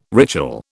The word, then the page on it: ritual: British English